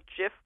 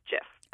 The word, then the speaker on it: GIF: The format was developed at CompuServe in 1987, and the programmers who developed it were very insistent that it be called GIF with a soft G. But the hard G sound is extremely well established, and it has the advantage of echoing the words of the acronym, which stands for Graphics Interchange Format. So we at Oxford Dictionaries give two equally acceptable pronunciations. But if you want the geek cred, then I would suggest going with GIF.